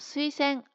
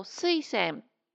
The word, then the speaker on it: 水仙: うん、でこの水仙アクセント 0 が標準の普通のアクセントです。だけど水仙水仙このように発音する人もあの結構いるようですね。うん、で私はねこっち派ですよ。水仙アクセント 1 派。